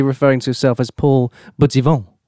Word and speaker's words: Buttivant: Now, can you spell it for me, please? – Yes, of course. A B U with the double T I V A N T. – Buttivant. – Buttivant, as it sounds.